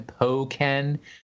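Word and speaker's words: Pokkén: Pokken.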